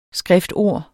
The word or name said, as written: skriftord